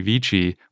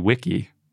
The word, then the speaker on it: vici: Another fun one is in the original Latin, veni vidi vici was probably pronounced veni vidi wiki. – Oh, that's different. – Because not only is the C a K, but V's were W's.